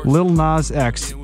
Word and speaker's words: Lil Nas X: Lil Nas X.